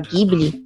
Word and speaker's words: Ghibli: Como você tá no Ocidente, você fala Ghibli, não tá errado. Na verdade, é a pronúncia correta. No Japão, que eles não conseguem falar o L, você fala Ghibli, mas é por uma questão fonética. Não é por uma questão correta.